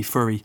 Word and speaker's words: Fourier: But actually, you mentioned that important word, which is Fourier. Apologies to anybody who speaks French, because that's an awful mangling in my Irish accent of F-O-U-R-I-E-R.